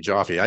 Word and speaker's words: Joffe: Yeah, it's actually like coffee, Joffe.